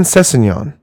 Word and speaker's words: Sessegnon: His name is Ryan Sessegnon. – That's right. – I'm pretty sure I'm saying that right. – I am almost certain you are.